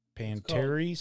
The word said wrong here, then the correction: pedantry: syndrome? – Pendant. Pedantry. – Pedantry syndrome.